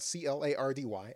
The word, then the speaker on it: Clardy: Uh, follow me on Twitter at Troy Clardy. Last name is spelled C L A R D Y at Troy Clardy.